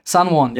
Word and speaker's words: San Juan: Yes, San Juan. I'm pronouncing it right. – San Juan, yeah.